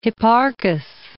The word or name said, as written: Hipparchus